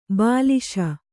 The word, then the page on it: ಬಲಿಸು: kannada; balisu